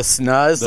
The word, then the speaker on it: snus: Is that how you pronounce that? Like, S-N-U-S? Those, like... – the snuzz? Like, the tobacco bags? – Is it snuzz? – Snuzz. – Is that it? – Yeah. – I call them snooze.